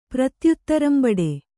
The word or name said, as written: ಪ್ರತ್ಯುತ್ತರಂಬಡೆ